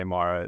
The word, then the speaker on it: Marra: I mean, Michael Marra, we actually don't even really know the correct pronunciation of my last name. It's Italian, so it's we say Marra, but a lot of people say Marra and stuff too.